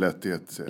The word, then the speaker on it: Lehtihet: Du, uttalar du ditt efternamn Lehtihet? – Ja, jag säger nog Lehtihet.